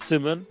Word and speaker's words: Simon: It's spelled Simon, but the way he pronounces it is Simon.